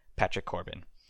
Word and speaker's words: Patrick Corbin: One thing I want to talk about is two of the premier pitchers of this year, Garrett Cole and Patrick Corbin. I assume I pronounced those names correctly. – You're 2 for 2 so far.